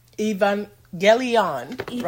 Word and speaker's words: Evangelion: Evangelion. – Right? – And it's spelled E-V-A-N-G-E-L-I-O-N. Evangelion.